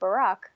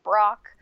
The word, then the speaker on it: Barack: But here, growing up with a name like Barack, you know, people didn't know how to pronounce it. They thought he was saying Brock. – Like B R O um, C K? Uh, B R O C K, yeah.